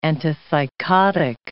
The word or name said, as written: antipsychotic